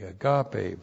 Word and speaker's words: agape: And then we have agape. And from our study on Thursday night, why, you know what agape is. A-G-A-P, long E. Agape, virtue love.